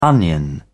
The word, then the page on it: onion: British English